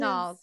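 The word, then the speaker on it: Nauls: One of my favorite parts of like the blood testing scene too is when they get to, is it Knowles? Is that how you pronounce it? Knowles, yeah.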